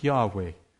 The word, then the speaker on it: Yahweh: We're not 100% sure how to pronounce it. Hebrew was written without vowels. And so we know the four consonants, and we think, scholars think it was pronounced Yahweh.